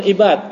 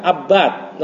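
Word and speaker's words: Abbad: Al-Imam Al-Bukhari fi sahihi ini bukan ibad, abad, nama seorang perawi. – An-abad. – Itu abad, jangan ubad, jangan ibad, abad.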